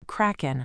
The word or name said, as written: kraken